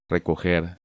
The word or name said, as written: Recoger